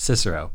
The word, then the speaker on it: Cicero: I think it sounds way better than Kikero. – Okay, cool. – All right, Cicero. But just in case any of you out there didn't know, that is not the classical Latin way of pronouncing that name.